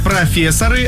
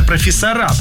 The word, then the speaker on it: профессора: Далее, вы сказали профессоры, а правильно профессора, поэтому здесь минус.